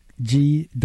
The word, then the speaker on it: G.HO.ST: Zvi Schreibler, CEO of G.HO.ST, pronounced Ghost, is one such talent magnet.